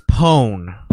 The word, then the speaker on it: pwn: Is it owns or pawns? How do you say that? – It's pwn.